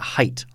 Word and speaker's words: Haidt: One of the things I enjoyed in this article – by Jonathan – pronounce it Haidt. – Haidt, yes. – H-A-I-D-T.